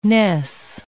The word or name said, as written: ness